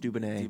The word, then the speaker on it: Dubonnet: It's Dubbonet, Dubonnet. We believe it's pronounced Dubbonet.